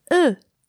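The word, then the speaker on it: eux: It is e. E as in de, but without the D, so it's spelled E-U-X, e. And the demonstrative pronoun associated to it would be ce, just C-E-U-X.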